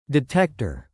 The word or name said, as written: detector